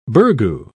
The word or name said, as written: burgoo